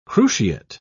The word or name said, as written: cruciate